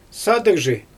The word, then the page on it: sadyGzhi: Xyzyl